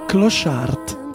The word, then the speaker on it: Clochard: La D in francese non si pronuncia. – Non è una D, infatti, è Clochard. È diverso.